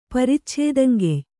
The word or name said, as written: ಪರಿಚ್ಛೇದಂಗೆಯ್